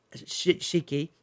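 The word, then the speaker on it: furoshiki: It's called furoshika. Shiki. Sorry. I apologize to our lovely speaker, Hiromi, for mispronouncing that.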